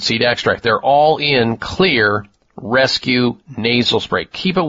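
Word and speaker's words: Xlear: is Clear Rescue Nasal Spray. Write that down. Clear is spelled with an X, X-L-E-A-R.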